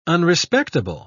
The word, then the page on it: unrespectable: British English